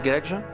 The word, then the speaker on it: Guedj: Je m'appelle Cédric Gage. Mon nom s'écrit G-U-E-D-J.